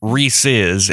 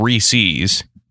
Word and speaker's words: Reese's: Because I wanted to teach you all that it's pronounced Reese's and not Reese's, you degenerates.